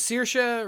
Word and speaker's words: Saoirse: Her name is Saoirse Ronan. – Yeah, right. And it is spelled though S A O I R S E, and I just the Gaelic way.